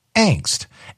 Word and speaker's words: angst: And with that, as well as going to the dictionary, there are some that will say angst is an acceptable pronunciation, but that the main pronunciation is angst. And so upon Steve's recommendation, I decided I'm going to change the way that I'm saying this word.